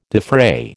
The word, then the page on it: defray: American English